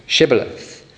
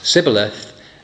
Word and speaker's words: Shibboleth: And whenever then the Ephraimites are trying to cross and get back to safety, they are asked to say this word, Shibboleth. And instead then they pronounce it Sibboleth.